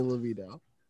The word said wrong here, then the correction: DeVito: Okay, first Danny of all, LeVito? You said it wrong. It's Danny DeVito.